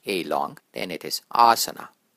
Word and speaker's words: asana: It's spelled as A-S-A-N-A. It's pronounced as asana. Asana. You'll hear many people pronounce it as asana. This is your first tip for correct Sanskrit pronunciation. Get to know which vowel is long and which one is short.